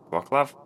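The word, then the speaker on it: Wrocław: Wroclaw? Is that how it's pronounced? – Go with it, yeah.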